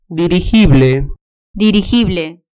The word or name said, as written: dirigible